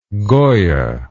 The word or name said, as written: goya